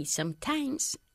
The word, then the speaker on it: sometimes: Okay, sometimes you have... – O mejor, sometimes, not sometimes... Sometimes, enfatizando la primera sílaba.